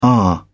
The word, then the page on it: are: British English